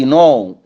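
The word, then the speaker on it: know: اي كلمه تبدا بكي ان ما ننطقش الكي، زي نو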